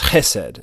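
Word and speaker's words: hesed (חסד): You may say it as hesed, you know, trying to do the Jewish, you know, mouth thing. I can't really do it. But that's how it's said, hesed, and it means God's covenant kindness, his mercy.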